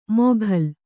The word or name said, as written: ಮೊಘಲ್